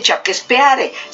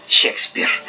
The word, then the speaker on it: Shakespeare: Pero en realidad no se dice Chaquespeare, se dice Shakespeare.